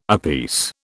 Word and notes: apiece: British English

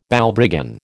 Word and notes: Balbriggan: American English

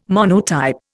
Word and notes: monotype: Indian

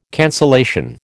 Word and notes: cancellation: American English

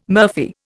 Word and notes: murphy: Indian

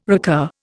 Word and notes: recur: Indian

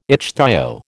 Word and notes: ichthyo: American English

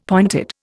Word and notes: pointed: Indian

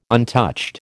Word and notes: untouched: American English

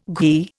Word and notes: ghee: Indian

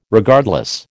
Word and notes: Regardless: American English

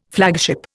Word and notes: flagship: Indian

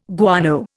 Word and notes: guano: Indian

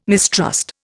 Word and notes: mistrust: Indian

